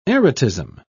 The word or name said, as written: erotism